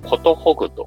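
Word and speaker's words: 寿ぐ: 実は私の名前に結婚式に出てくる寿の漢字が入ってるんですけども、この寿とですね、あと同じこの寿の漢字はことほぐという読み方があることを教えてもらいまして、寿とことほぐを掛け合わせた造語として寿という社名を付けました。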